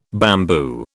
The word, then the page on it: bamboo: British English